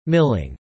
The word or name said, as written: milling